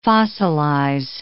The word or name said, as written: fossilize